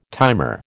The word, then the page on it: timer: American English